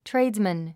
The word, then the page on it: tradesman: tréidzmən; トレイズメン